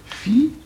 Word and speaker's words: fiancé: Alors, il établira cette prononciation fiancé. C'est-à-dire qu'au lieu d'un iode, il oblige à prononcer un ni.